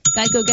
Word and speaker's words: Geico: Geico Gecko. – That's correct, hey. The original idea was that a gecko could help people remember the name Geico, which often got pronounced like gecko.